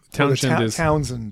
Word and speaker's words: Townshend: Townshend duties is usually how it's pronounced. Okay. But it's spelled with shend, but usually they don't say it.